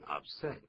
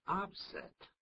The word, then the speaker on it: upset: 当形容词念成 upset，当名词念成 upset。